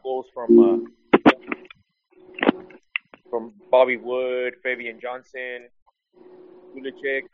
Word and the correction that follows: Pulisic: uh, or whatever you say his name, uh, Julian Green. Pulisic and uh, and uh, and uh, and uh, Julian Green.